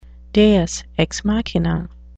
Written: deus ex machina

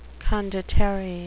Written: condottiere

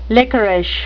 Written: lickerish

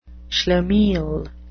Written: schlemiel

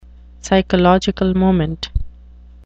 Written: psychological moment

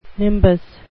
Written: nimbus

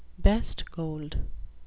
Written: best gold

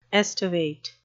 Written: estivate

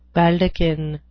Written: baldachin

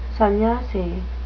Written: sannyasi

